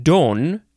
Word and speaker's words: dom: It is spelled capital D-O-M. It is from 1716. It's not a noun or anything like that. So number one is pronounced Dom. That's how I would have wanted to pronounce it. It is used as a title for some monks and canons regular. And number two, now this one is pronounced Don. Now, but there's an N sound instead of an M sound,